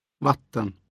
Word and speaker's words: vatten: For now, learn to pronounce the words for sugar, water, and bicycle with accent one, a single rise. – Socker. Vatten.